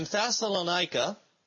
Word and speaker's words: Thessalonica: But in Thessalonica, I think I said it right that time, you've got this delegation of unbelieving Jews who stir up trouble in the city.